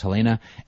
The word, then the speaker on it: Helene: It is Helena St. James, H-E-L-E-N-E, although pronounced Helena, S-T James on Twitter.